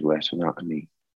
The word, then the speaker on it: retinopathy: retinopathy. No, I didn't say that right. Retinotomy, I think it's called. – Retinopathy.